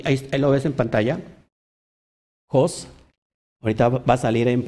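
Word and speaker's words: jos (ὅς): Y la palabra que tú ves griega es jos, que se escribe como si fuera, se ve como si fuera una OC, pero en realidad es Jos.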